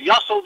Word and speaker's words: Yossel: It happens to rhyme with hustle and move a muscle. So he used the word Yossel. But the Yossel there is talking to everybody.